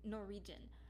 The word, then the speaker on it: Norwegian: I think I said it right the second time. Norwegian. Norwegian. Okay, it sounds like there's an R, another R. It sounds like there's two R's, but there's a W there.